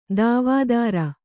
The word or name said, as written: ದೇವದಾರು